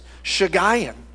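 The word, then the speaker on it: Shiggaion: Went on there and how do you say this? Because I was like, I could say this about 100 different ways. Shigayon is what the guy sold me on the web, okay?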